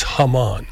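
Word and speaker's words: jamón: But the it's spelled Jamon, but it's Hammann.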